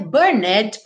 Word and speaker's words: burned: Você não vai dizer burned, porque se é, é mudo, certo? Então você tem burned, terminando em D, e na sequência, the.